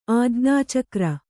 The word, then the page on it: ಆಜ್ಞಾಚಕ್ರ: ājñācakra